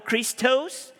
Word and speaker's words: Christos: And you can't pronounce that word Christos. No, it's Christos. Christos, which is where we get the name Christ.